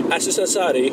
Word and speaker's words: Aziz Ansari: Oder wie man in seinem Land ausspricht, Aziz Ansari. – So spricht man das aus.